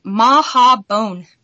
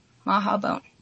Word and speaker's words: Mahhabone: The word is Maha Bone. – Maha Bone. How do you, can you spell that for us? – M-A-H-H-A-B-O-N-E. Maha Bone. – Maha B-O-N-E.